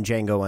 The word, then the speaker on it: Django: The D is silent in Django Unchained.